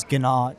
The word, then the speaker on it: Guina: So my last name is Guina. It's spelled G-U-I-N-A, and it's not a very common name.